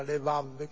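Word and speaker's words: levav (לבב): Cuore in ebraico, che si scrive anche levav, scritto lebab, si pronuncia levav,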